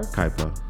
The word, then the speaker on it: Kuiper: I don't think that's how you pronounce Kuiper, it, bro. Yeah. Was it C-U-I-P-R? – Kuiper. – Kuiper? – Yeah. – What? – Yeah, Kuiper. Kuiper? Windshield wiper? – Windshield wiper.